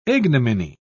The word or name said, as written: ignominy